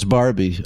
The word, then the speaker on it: Barbie: And then I've always said Borby. Is that how you say Borby? – Borby? – Oh, it's Barbie.